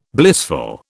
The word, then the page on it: blissful: British English